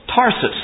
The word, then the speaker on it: Tarsus: Paul was from, do I say that right, Taurus? Tarsus, that's right. Thought there was something wrong.